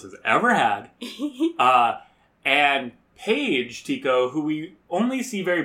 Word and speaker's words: Tico: Tika. – Tika? – I believe it's Tico. It's spelled T-I-C-O.